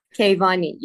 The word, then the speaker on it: Keyvani: Your last name, Keyvani? I don't want to butcher it. – Oh, Keyvani? – that was the best accent of my family name I've ever heard.